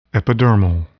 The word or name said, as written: epidermal